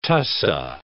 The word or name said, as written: tussah